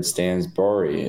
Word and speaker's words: Stansbury: Dude, every time I just read your name, Jared Stansberry, it should be. Not Barry.